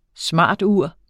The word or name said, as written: smartur